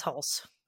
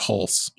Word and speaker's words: Hulce: Starring Tom, it's either Hulse or Hulce. – I'm pretty sure that's Hulse. – I'm pretty sure it's Hulse.